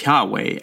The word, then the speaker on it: Yahweh: Another common way of pronouncing the name is Yahweh, adding to it some of that vowel sound.